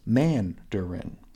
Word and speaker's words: Mandarin: And also, it's Mandarin, with emphasis on the first syllable.